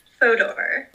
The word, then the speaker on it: Fodor: F-O-D-O-R. Fodor. – Fodor. Not like Hordor from Game of Thrones, but with an F.